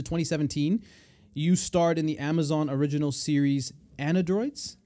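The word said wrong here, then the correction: Annedroids: Androids. Yeah. – But it's spelled with an A-N-N-E, Androids.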